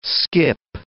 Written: skip

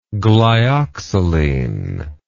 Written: glyoxaline

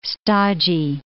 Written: stodgy

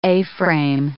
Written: A-frame

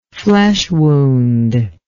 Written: flesh wound

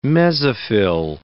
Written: mesophyll

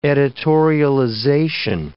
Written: editorialization

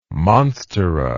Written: monstera

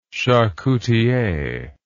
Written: charcutier

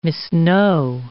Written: misknow

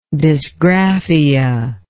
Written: dysgraphic

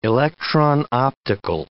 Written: electron-optical

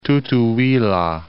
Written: Tutuila

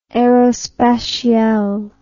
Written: aerospatiale

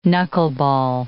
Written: knuckleball

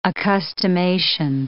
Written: accustomation

